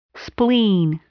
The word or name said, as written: spleen